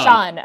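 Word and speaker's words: Siân: Yeah, and the one other person that I in particular definitely wanted to highlight was, I'm probably pronouncing her name wrong, which I feel very bad about, but Sian Phillips? – Ah, Sean. – Sean, okay, yes, thank you your Welsh education.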